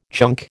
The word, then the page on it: chunk: American English